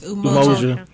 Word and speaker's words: Umoja: It's Umoja.